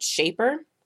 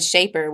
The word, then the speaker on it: Shapr: which is called Shaper. So there's Bumble, like Bumblebee, so and Shaper, which is spelled SHAPR.